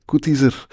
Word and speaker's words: Coudyser: Onze gast vandaag is Cathy Koetizer. Dag Cathy, welkom in onze, in onze studio. Ja, ik zeg Cathy Koetizer, omdat je het zo moet uitspreken, maar je schrijft eigenlijk koud ijzer.